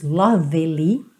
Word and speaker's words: lovely: você também não diz lovely, tá bom? Esse E tá pintadinho de cinza, porque não é pronunciado.